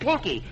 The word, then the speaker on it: Pinky: I said Pinky with an I, not a U.